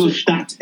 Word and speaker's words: Ingolstadt: Is that how we pronounce Ingolstadt.